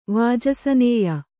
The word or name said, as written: ವಾಜಸನೇಯ